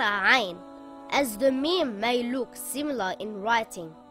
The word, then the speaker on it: Mim: It is a light letter and should not be pronounced heavy. Mim makes a similar sound in English to the letter M in the word moon or the word mouse.